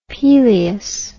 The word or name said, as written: Pelias